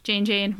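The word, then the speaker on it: Jeyne: Jane, rhymes with pain.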